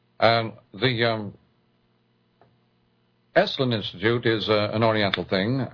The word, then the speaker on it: Esalen: Esalen, pronounced like wrestling, where people go out to wrestle with their inner problems.